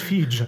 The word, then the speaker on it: Feige: Nein, der wird Fiji. Fiji.